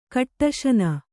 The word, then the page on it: ಕಟಿಸಾನು: kaṭisānu